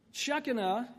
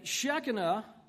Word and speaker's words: Shekinah: Shekinah is how my wife taught me to say it this morning, and then I promptly forgot. Okay, Shekinah, or glory of God.